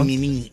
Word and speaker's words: MININT: del MININT. Así se pronuncia, ¿no? MININT. Eso.